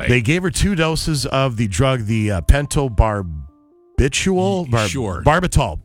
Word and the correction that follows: Pentobarbital: Pentobarbital is how you say it.